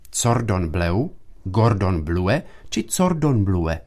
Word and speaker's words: Cordon bleu: Často špatný zápis a s tím spojená výslovnost jsou zapříčiněny podobností anglického a francouzského výrazu pro modrou barvu blue a bleu. Výše představené slovo však pochází z francouzštiny, tudíž jeho správná francouzská výslovnost bude Cordon bleu.